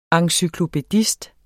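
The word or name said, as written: ENCYKLOPÆDIST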